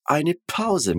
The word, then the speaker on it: Pause: A gente não vai falar pause, né? Pause. A gente vai falar pause. Pause. Percebe que o P ele é bem explosivo e a gente tem a terminação com E, né? Não com A.